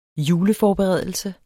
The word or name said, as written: juleforberedelse